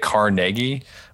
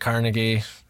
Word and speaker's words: Carnegie: I think it's Carnegie or how do you say it? Carnegie? – Well, I say it Carnegie. NPR says it Carnegie.